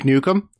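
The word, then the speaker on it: Nuke 'Em: Duke Nukem? – Duke. Yeah, it's spelled like that, too. – Nice. – N-U-K-E apostrophe Nukem. Kevin Nukem.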